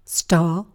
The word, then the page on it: star: British English